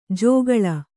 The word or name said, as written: ಜಗಳ